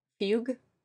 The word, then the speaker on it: Fugue: F-U-G-U-E 55? Nope, go first. How do you pronounce that sniper rifle? – Fugue. – How do you pronounce it? – Fugue. – I like it. – Fugue, Fugue, how do you pronounce it? It's French, apparently.